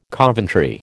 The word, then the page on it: Coventry: American English